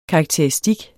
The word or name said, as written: Karakteristik